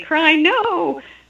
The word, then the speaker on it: Chrino: And I have a new little meme, Crino, C-H-R-I-N-O, and you can think of it phonetically, Crino, but that's Christian in name only.